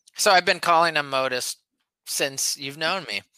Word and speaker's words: Modist: First, that's how you pronounce the name because the brewery is spelled M O D I S T, but modest.